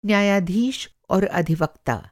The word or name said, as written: अधिवक्ता